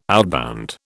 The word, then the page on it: outbound: British English